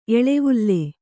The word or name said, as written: ಎಳೆವಲೆ